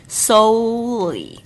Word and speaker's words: solely: 再来是 solely，记得这个 so o 的这个发音，把它发得很全，它是一个双元音哦，o 的声音 solely。